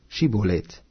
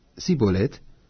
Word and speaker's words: Schibolet: Wenn er dann antwortete: Nein, ließen sie ihn sprechen: Schibolet. Sprach er aber: Sibolet, weil er es nicht richtig aussprechen konnte, Dann ergriffen sie ihn und erschlugen ihn an den Furten des Jordan, so daß zu der Zeit von Ephraim fielen 42.000.